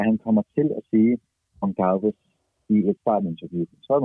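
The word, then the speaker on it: Gonçalves: altså, øh, han, han udtales Gonsalves, og det er bjerg, jeg kommer til at dø på.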